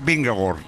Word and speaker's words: Vingegaard: ¿Cuál es la pronunciación correcta? – La pronunciación correcta es Vingegaard.